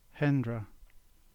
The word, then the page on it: Hendra: English